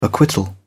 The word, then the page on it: acquittal: British English